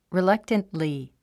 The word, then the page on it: reluctantly: rilʌ́ktəntli; リィラクタントリィ